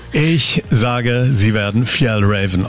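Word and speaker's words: Fjällräven: Und es wird richtig ausgesprochen, Fjell Raven. – Fjell Raven. – Als S werden im Deutschen als S ausgesprochen.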